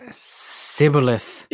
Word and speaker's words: Shibboleth: They pronounced the SH sound as just an S sound. – Shibboleth.